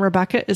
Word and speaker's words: Rebecca: And Rebecca is spelled R-E-B-E-C-C-A. Ryan, like the boy's name, R-Y-A-N.